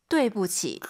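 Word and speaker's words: 对不起: I, U 사이에 O. 그리고 U, I 사이에는 에. 뚜이부치가 아니라 뚜이부치. – 그렇죠.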